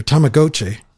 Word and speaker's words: Tamagotchi: It's advertised, like I said, as Tamagotchi. I can't pronounce it correctly. My daughter corrected me earlier. Tamagotchi.